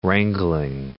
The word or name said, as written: wrangling